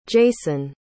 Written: jason

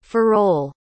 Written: ferrol

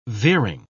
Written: veering

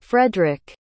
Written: fredrick

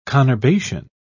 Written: conurbation

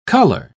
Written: color

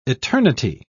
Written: eternity

